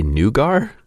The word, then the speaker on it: nougat: You say nougar? – Yes. The correct French pronunciation.